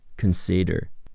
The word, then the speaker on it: consider: o 是 l，d 是 d，child，h 是 h，u 是两个 o，h u hu，d 是 d，hood，childhood，childhood，动词注视、仔细考虑，consider，k a k 是 c o，n 是 n，k n，c a c 是 s i，的 er 的是 der，重音在 c， consider consider。